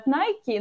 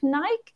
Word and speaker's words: Nike: в Америке это называют Nike, но это против правил грамматики и произношения, потому что like пишется так же, как Nike, и поэтому произносить нужно Nike, и поэтому я э, привыкла к э, такому способу, хотя понимаю, что изначально по, по задумке это действительно э, Nike.